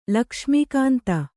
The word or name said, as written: ಲಕ್ಷ್ಮೀಕಾಂತ